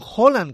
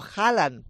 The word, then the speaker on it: Haaland: Pues que digan Haaland, que es como se dice en noruego, y no Haaland.